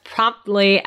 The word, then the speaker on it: promptly: So yeah, you can't really pronounce that T. So it's one of those awkward words that you know there's a T there, but we don't really pronounce it, but we kind of have a bumper there that we stop promptly. So the T doesn't really come out, but there's a stop there.